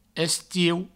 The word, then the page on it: estiu: Provençal; estieu